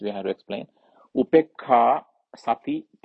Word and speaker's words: sati: Upekkha. UPEKKHA long A upekkha dash SATI sati dash, because this is one word, supposed to be one word, but so I separate PA long A RI SUDDHI parisuddhi.